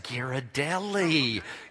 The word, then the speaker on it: Ghirardelli: You could have had a Ghirardelli. Is that how you say it? – Mm-hmm. – No, you say Ghirardelli.